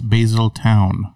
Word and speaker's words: Basildon: Did I say it right? – No, it's Basildon. – No, it's Basildon. I was calling it Basildon, probably because of Basildon. I was calling it Basildon, and she said, it's not Basildon, it's Basildon.